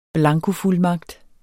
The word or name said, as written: blankofuldmagt